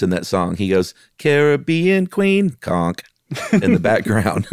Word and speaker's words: Caribbean: and this conch mollusk, the queen conch is in the Caribbean, or is it Caribbean? Because I heard someone say it once. – It's the Caribbean. Yeah, you have to say it like you're about to sing the Billy Ocean song.